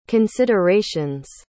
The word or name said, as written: considerations